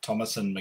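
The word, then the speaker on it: Thomasin: And I have to say, is it Thomason? Is that how you say her name? – Thomason McKenzie.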